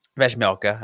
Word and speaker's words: Vejmelka: I'm going to try to get this right. Um, Vejmelka. I was really close. I just had the A at the end.